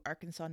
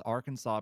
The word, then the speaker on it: Arkansas: Why is Arkansas pronounced Arkansas and Kansas is pronounced Kansas? – That's a great question.